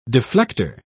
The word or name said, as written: deflector